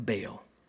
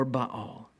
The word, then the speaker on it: Baal: And then we have Elijah, who meets with God on top of Mount Carmel in defeating the prophets of Baal, or Baal, depending on how you want to pronounce it.